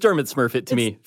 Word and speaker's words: Smurfit: And Smurfit, Smurfit really is spelled S-M-U-R-F-I-T.